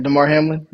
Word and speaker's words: Damar Hamlin: Now, I'm sure I'm probably going to pronounce his name wrong, but Damar Hamlin, is that how you say his name? – Yeah, Damar Hamlin.